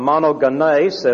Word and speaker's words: monogenes: Only begotten comes from monogenes. It has 4 syllables.